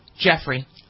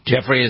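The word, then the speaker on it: Giuffre: Is it Griffin? How do you pronounce it? – Jeffrey. – Jeffrey is how you pronounce it? – Yeah.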